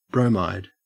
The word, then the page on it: bromide: Australian English